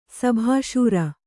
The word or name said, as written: ಸಭಾಶೂರ